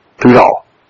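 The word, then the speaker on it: todah: In the Hebrew, T-O-W-D-A-H, pronounced like two, T-O-D-A-H, D-A-W, Tudah, or Tal rather, not Da, Da, Tudah.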